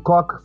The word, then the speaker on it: Koch: They tried teaching us how his name is pronounced, because it's K-O-C-H. So some were saying Koch, some were saying coach. So it's Koch.